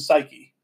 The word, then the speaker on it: Psyche: Lewis had read the story of uh, uh, Cupid and Psyche. – Yeah. – Okay. Or Suke, if you're pronouncing it, you know, in Greek terms.